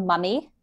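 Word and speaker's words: momme: So it's called mummy, pronounced like, you know, an Egyptian mummy.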